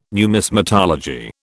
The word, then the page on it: numismatology: British English